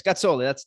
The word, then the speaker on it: Gazzoli: Is it Gazzoli, or do you pronounce it the Italian way? – You said it the right way. You know, it depends which Italian person you ask. Gazzoli, that's correct. But I Gazzoli, think, you know, yeah. Most people just go Gazzoli, so unfortunately. But appreciate you doing the right one.